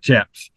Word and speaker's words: chaps: And for some people listening, when he's saying chaps, he's talking about chaps, and it's not chaps. It might have a CH on it, but it's pronounced with an SH.